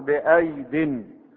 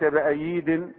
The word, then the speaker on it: بأيد: لكن الياء التي بعد الياء لا تنطق وإنما نقرأها على أنها ياء بأيد ليس بأييد، لا وإنما تقرأ بأيد،